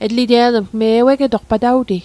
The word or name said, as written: ewegetoq